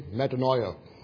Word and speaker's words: metanoia: As we think about repentance, the Greek word for repentance is metanoia, or pronounced properly in the Welsh way, metanoia, which is made up of two parts, meta meaning change, noia meaning mind.